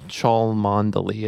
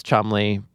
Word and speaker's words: Cholmondeley: I was told the pronunciation is Cholmondeley, but the spelling of that is like wild and is like going to, you know, is giving me a panic attack just looking at it. – It looks like there are like eight different syllables. – Yeah, Cholmondeley is how it looks. But I've been told it's Cholmondeley. British listeners, correct me if I'm wrong.